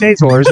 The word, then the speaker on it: Azores: Are you mispronouncing assholes? – No, it's Azores. It's Azores Islands. That's how you say it. Whatever. – What did I say? Azores or something like that? I put the emphasis on the wrong syllable.